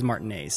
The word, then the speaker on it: Martinet: They're just straight from the man's mouth himself. He says Martinet, so we've been sort of saying it wrong.